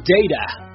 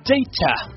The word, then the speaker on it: data: 手机的流量叫做 data， data 这是美式发音， data 这是英式发音，你记住了吗？